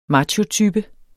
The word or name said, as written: machotype